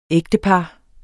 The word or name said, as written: ægtepar